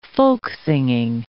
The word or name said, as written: folksinging